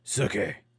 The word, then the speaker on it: Sookie: You're saying it wrong. Yeah, dude. Sookie.